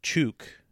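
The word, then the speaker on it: Chuuk: So it's spelled differently, so it has two different spellings. – So it's Chook and Truk.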